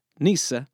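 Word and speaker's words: nyce: In the Canterbury Tales, nice, which, by the way, was originally spelled N Y C E, N would have been pronounced Nisa, is used several times according to its original definition, meaning stupid or foolish.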